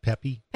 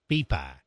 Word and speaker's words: Pepi: It's pronounced Pepi? – Pepi. – Pepi, P-E-P-I. – Like okay. Pepi Le Pew. – Okay. Down in the South, people might say pea pie.